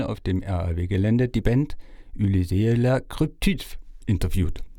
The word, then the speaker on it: Ulysse: Ulysée la Cryptide. So Ulysse is my name. – Ulysse le Cryptide. Ulysse ist mein Name, so wie Odysseus, aber halt auf Französisch.